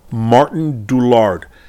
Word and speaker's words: Martijn Doolaard: And his name is pronounced, Martijn Doolaard.